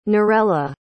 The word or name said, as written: narella